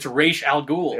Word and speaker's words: Ra's al Ghul: It's like, that's not how you pronounce the fucking name. – Right. And in the language it's supposed to be, it's still Ra's, right? – Yeah, it's Ra's. That's the fucking name. It's Ra's al Ghul.